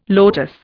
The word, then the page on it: lotus: Indian